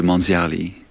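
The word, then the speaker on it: Manziarly: And I'm guessing that the next composer that you have for us is a relatively new one, Manzier Lee. And I believe you seem to have the inside track on him, so let's get the word. – So how to pronounce it is Marcel de Manzierli.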